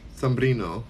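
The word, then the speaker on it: sobrino: Holly Jackson says, you are an uncle to one sombrero? Sombrino. It's sombrino. That's nephew in Spanish.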